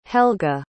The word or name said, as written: helga